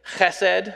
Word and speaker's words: chesed: And because of my Scottish background, and we like to make this sound, I'm going to insist that we pronounce it the Hebrew way with the fricative at the beginning, chesed. If you have trouble with that, you just have to take the back of your tongue and push it up towards the top of your mouth.